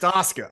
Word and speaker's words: Asuka: That we had been pronouncing Asuka incorrectly for about 20 weeks straight, and she'd really finally had it. – Yeah. Asuka. – Yeah. Yeah, it's Asuka.